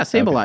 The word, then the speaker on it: Sableye: Yeah, Sableye.